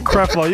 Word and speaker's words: Creflo: How do you say it? – Creflo. You got it right, Fred.